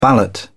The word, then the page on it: ballot: British English